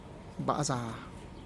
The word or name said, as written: bazaa